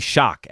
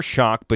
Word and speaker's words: Shough: Shock? I think it might be Shock, actually. S-H-O-U-G-H, but I remember seeing a pronunciation guide.